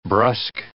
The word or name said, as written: brusque